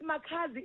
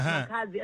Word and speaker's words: Makhazi: It doesn't matter how you pronounce it. Even how you pronounce it before, it's still the same. It's Makhazi. As long as there is Z at the end, that's my name.